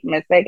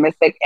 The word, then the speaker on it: Missick: But if you look at my name as well, Faith Missick, F-A-Y-T-H-E, and then Missick, M-I-S-S-I-C-K, you can see a few of my books.